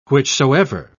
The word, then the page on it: whichsoever: British English